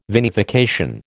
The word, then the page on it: vinification: American English